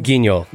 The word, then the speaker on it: Guignol: Is that how you pronounce, like, Grand Guignol? – Guignol.